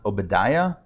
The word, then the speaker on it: Obadiah: It's his Hebrew name, Ovadia. In English, I think it's pronounced Obadiah. I think that's the English way of pronouncing it.